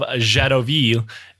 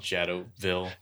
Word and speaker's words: Jadotville: And I'm not going to pronounce it like that the whole episode. It is... – It's called Jadotville. – Yeah, it's French-Belgian. But, yeah. All the documentaries just call it Jadotville.